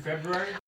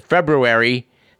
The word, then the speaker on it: February: We do like to remind our listeners on a yearly basis that February has two R's and both are pronounced. But of course, as always, when someone says February, be diplomatic if you elect to correct them.